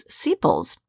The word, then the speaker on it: sepals: Those pieces of that covering are known as sepals. Yeah, rhymes with steeples, right?